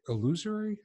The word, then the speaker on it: illusory: Illusory? I don't know how to say it. Illusory. Yeah.